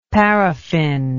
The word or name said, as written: paraffine